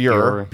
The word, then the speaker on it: Bure: I called him Pavel Bure because it looks like pure, but pure, it's Bure, B-U-R-E.